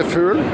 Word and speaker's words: Fugl: We say in Norwegian, we say Fugl, but in American, it's usually Fugl or Fugl. – Because we kind of kill it in that way. – Yeah, you can't really pronounce the U. You say, we say ooh. You can't say that. Can you say that? Ooh.